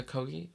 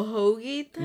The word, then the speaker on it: Kogi: The Kogi? Like... – Oh, a hoagie? – No, Kogi. I think I'm saying it right.